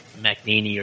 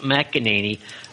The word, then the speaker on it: McEnany: We played Kayleigh McEnany, and I think yesterday I spent all day calling McNaney or something. I realized when I was listening to the show that I pronounced her name wrong, and I apologize for that.